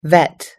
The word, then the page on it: vet: American English